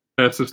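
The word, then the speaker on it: Thurstaston: No, how do you actually say it? – Cancel the show. So, Thursasten first one. Come on, number two. – Okay, how do you say how do you say it? – Thurston. – The first one. Thurston. I got it.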